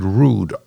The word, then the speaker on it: Rood: And then the other gentleman is Mr. Franklin, I'm going to pronounce it Rood, R-O-O-D, and he is from Canton, New York.